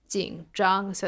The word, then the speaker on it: 紧张: Okay, 紧张. So that's third tone and first tone.